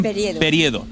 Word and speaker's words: Periedo: Es alcaldesa de Casar de Pariedo. ¿Lo he dicho bien? – Periedo.